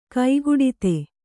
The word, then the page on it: ಕೈಗೊಡತಿ: kaigoḍati